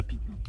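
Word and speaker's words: pepperoni: Peperoni, peperoni. En inglés, lo, no, porque dijo en inglés. En inglés, se pronuncia pipironi.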